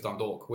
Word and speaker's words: Dundalk: Am I saying that right? Dundalk? – Well, it depends. Dundalk? But I think it's Dundalk. We say Dundalk. In England, we don't have an Irish accent, so no, true, they may say it differently, but he joined town off.